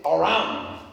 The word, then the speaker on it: Aram: So in Second Kings chapter 6, a war is going on um, an ancient Aram, that's how the name is pronounced, was at war with Israel.